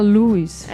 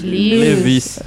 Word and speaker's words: Lewis: C.S. Como é que fala? Lewis. É. Lewis. – Lewis. Lewis. É o Tio Jack. C.S. Lewis.